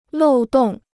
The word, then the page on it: 漏洞: lòu dòng